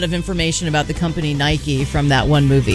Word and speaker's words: Nike: Is that how it's pronounced? Nike? – I don't know. I'm going to say it. – I assumed it was Nike because that's how we say it. That's how it is pronounced.